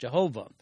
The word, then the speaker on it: Yahweh: But he also addresses him with the word Lord, all caps, from the Hebrew word Yahweh, sometimes pronounced Jehovah,